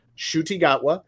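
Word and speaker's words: Ncuti Gatwa: He is an actor named Shuti Gatwa. I looked up the pronunciation. That's how you pronounce it. Shuti Gatwa.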